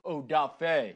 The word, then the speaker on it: Odafe: I like that he was, like, correcting people on his name. They're like, he's like, Odafe. – Yeah, yeah. – You pronounce it right, mister.